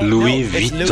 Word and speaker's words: Louis Vuitton: Louis Vuitton. – These guys Vuitton. He says it too. The guy, the pronunciation is Louis Vuitton.